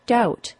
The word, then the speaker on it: doubt: Two other words that were messed up by the scholars were the words debt, D-E-B-T, and doubt, D-O-U-B-T. Neither of those words have a B sound either.